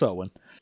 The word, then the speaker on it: Eogan: I mean, we established it's Owen. – It's what? Oh, Owen? Right, you're right, because it's like a Celtic pronunciation. – Yeah. – So how do you pronounce the other one? Artagan and Owen, or Arden? – I don't know. – From now on, I'm just going to... – I couldn't find a pronunciation for that one. Like, I looked up the Eogan thing, and it was like, oh yeah, no, that is Owen.